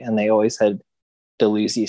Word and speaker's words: Dalhousie: and like the pronunciation of Dalhousie or Dalhousie, or as I found recently, a different one, Dalhousie.